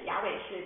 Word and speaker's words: Yahweh: 而且就是说他都不仅仅依靠，他是说什么，他在主里面，他说 in the Lord in Yahweh， Yahweh 是神的名字，在 Yahweh 他的神里面他找到了力量。第一 Yahweh 就是 Yahweh 其实就是耶和华，大家认为的耶和华，但是 Yahweh 其实是希伯来语里面最的应该最靠近的一个发音，所以他说在亚伟他的神，亚伟是他的神，所以耶和华或耶稣有没有成为我的神，还是我听说的，或是我妻子的，或是我丈夫的神。